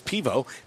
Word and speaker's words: pivo: This is mostly Czech-inspired fare here at Pivovar, because Pivo is how you say beer And check and var is how you say bar.